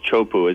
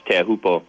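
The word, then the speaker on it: Teahupo (Teahupoʻo): everyone calls Chopu. It's actually pronounced Teahupo,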